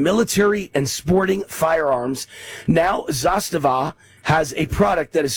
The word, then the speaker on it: Zastava: How do I pronounce it, Pitar? – Zastava.